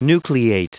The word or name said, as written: nucleate